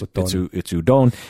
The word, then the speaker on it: udon: Well, even my name, that's not how you pronounce it. – So it's futon. It's udon. – Because it's very Latin sounding vowels, which is very odd.